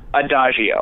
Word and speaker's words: Adagio: You guys have the new, and I'm not perfect on the pronunciation, but I think it's the Agio glider that you guys have coming. Is that correct, the name? – Adagio. – Adagio.